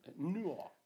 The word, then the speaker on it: Newark: That's uh, new, eh, it's not New York, Newark, uh, New Jersey, and Jan Grue eh, living in Oslo.